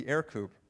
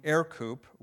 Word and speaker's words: Aircoupe: In this case, the Forney Aircoupe, which is pronounced the same way, but it's spelled A-I-R with a coupe on the end.